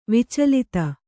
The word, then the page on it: ವಿಚಲಿತ: vicalita